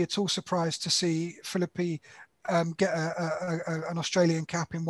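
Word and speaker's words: Philippe: But the interesting one who managed to edge into the top five was Josh Phillippe, I think you pronounce his name. Josh Phillippe. – Yes.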